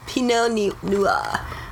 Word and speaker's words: Pinot Noir: Pinot Noir. You just gotta pretend you're French. Pinot Noir.